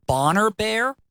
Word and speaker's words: Boner Bear: It's spelled B-O-N-E-R-B-E-A-R, but I believe it's pronounced Bonner Bear in honor of Bonner Springs.